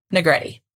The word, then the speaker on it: Negrete: Negrete mm-hmm. Spaghetti. – Yep. – It rhymes with spaghetti.